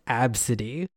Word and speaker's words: Abcde: Absidy. – Oh, no. – There are over 300 girls with that name in the States. – What? – It's written A, B, C, D, E. And pronounced Absidy.